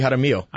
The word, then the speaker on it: Jaramillo: Is that how you pronounce it? – Rudy Jaramillo. – I thought it was Jaramillo.